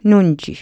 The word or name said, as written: nunji